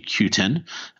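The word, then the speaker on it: Qoo10: And the name of the marketplace, I don't know how to say it, so I'll spell it, is Q-O-O and then 10, which, you know, so I think it would be Q10 .jp.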